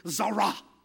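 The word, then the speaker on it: Zara (צָרָה): That word distress is a Hebrew word and it's T-S-A-R-A-H. The T is silent. The S is like a Z, so it's Zara.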